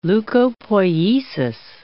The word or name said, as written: leukopoiesis